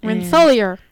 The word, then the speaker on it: Rensselaer: Rensselaer.